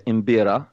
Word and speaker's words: mbira: Okay, so this first instrument is called the imbira, and that's spelled M-B-I-R-A. That's M as in Mary, imbira.